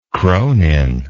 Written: cronin

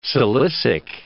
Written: silicic

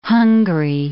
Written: Hungary

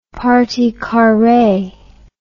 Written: partie carree

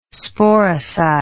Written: sporicide

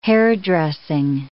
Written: hairdressing

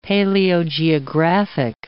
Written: paleogeographic

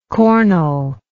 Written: cornel

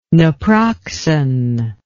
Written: naproxen

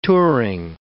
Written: touring

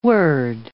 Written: word

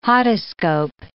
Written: hodoscope

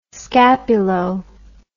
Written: scapulo

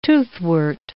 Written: toothwort

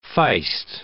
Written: feist

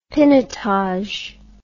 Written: pinotage

